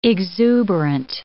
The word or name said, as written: exuberant